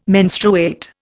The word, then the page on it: menstruate: Indian